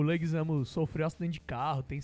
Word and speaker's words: Leguizamo: O que ele fala, o Bob Hoskins, é o seguinte, eu não consigo pronunciar o nome daquele cara que faz o Luigi, é Leguizamo, Leguizamo.